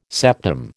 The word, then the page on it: septum: American English